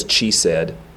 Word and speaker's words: chesed: The word merciful is rachum, R-A-C-H-U-M. – Can you spell that one for loving kindness? – C-H-E-S-E-D. You're welcome. Looks like it says chesed, but it's chesed.